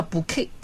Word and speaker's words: bouquet: 花束叫 bouquet, 也是不发音的。